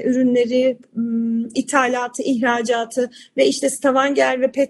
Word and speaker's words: Stavanger: Ben Norveç'e geldiğimde hani bana Oslo tabii ki de bir şehir ama şimdi karşılaştırıyorum Oslo dışında Bergen'i eğer doğru telaffuz ediyorsam Stavanger'i falan hadi.